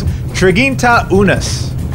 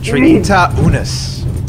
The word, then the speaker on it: triginta unus: Triginta unus. Triginta unus.